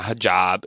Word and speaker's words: hijab: She was in the hijab.